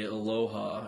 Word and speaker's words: aloha: Plus, you get to say aloha everywhere you go.